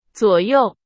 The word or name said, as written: 左右